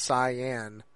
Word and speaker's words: Saiyan: And yeah, you got Al Roker that apparently, like, he pronounced the term Saiyan correctly, and it's not Saiyan, it's Cyan.